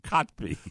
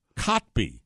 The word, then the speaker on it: Kotb: Uh, Hoda Kopke, uh, Hopke, whatever she, I don't know how to say her name. – Kotb, not Hopke. What is it? Kotb. Kotb? K-O-T-B.